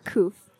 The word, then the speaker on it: Coufe: name is Christine, also known as Coufe or Kouf on Instagram.